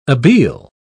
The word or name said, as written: abele